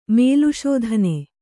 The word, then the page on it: ಮಲಶೋಧನೆ: mala śodhane